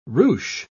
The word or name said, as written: ruche